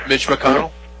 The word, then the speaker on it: McConnell: Mitch McConnell? – Yep.